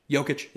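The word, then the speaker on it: Jokić: Yeah, wait, we're at the parade and how do you pronounce his name? Jokic? – Jokic, yeah.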